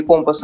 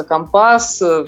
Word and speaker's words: компас: Не компас, а компас.